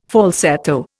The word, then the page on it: falsetto: Indian